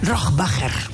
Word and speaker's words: Rohrbacher: Rohrbacher.